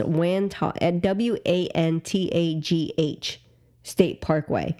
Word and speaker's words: Wantagh: over the Wantagh State Parkway when he struck an overpass in East Meadow and attempted to flee was allegedly drunk.